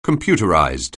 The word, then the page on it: computerized: anglais